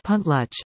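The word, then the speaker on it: Pentlatch: There are now two semi-speakers of the language and about 20 people learning Pentlock, pronounced Puntluch, after the last speaker died in the 1940s.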